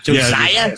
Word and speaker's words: Josiah: And, of course, we're going to talk a little bit about Ryan Kerrigan, Casey Tucker, and Josiah Scott. Is that how you say it? – Josiah Scott.